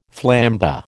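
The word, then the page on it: flambé: American English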